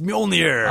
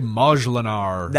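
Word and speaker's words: Mjölnir: Is it Mjolnir, really? – It's Mjolnir. – I always want to say Majlinar. – That is not accurate.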